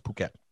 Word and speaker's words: Phuket: It's pronounced Phuket.